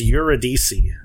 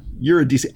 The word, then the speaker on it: Eurydice: Wikipedia says it's Eurydice. – Yeah, Eurydice. I swear when he said it, it had so many more syllables than that.